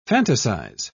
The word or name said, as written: fantasize